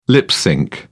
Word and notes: lip-synch: British English

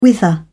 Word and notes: whither: British English

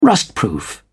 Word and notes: rustproof: British English

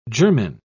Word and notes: germen: British English

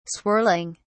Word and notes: swirling: British English

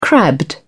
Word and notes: crabbed: British English

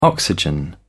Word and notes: oxygen: British English

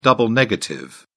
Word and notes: double negative: British English

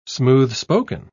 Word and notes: smooth-spoken: British English

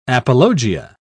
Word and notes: apologia: British English

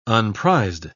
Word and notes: unprized: British English

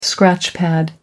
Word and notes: scratchpad: American English

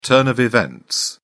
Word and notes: turn of events: British English

